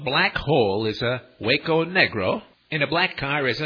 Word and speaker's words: negro: Well, it's always just translated to the word negro, pronounced negro in Spanish, meaning simply the color black, or a black person.